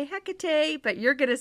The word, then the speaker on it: Hecate: and the original way is Hecate.